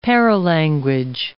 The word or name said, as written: paralanguage